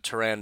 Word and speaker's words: Tyrann: I know I was told by a listener that that's not how you say it, but I looked it up, and they say you're supposed to say it like Byron, but it's with a T. That's what Google says, so his name is now Tyrann.